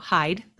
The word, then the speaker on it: Hejde: My last name is impossible to pronounce if you read it. So Hejde, that's it.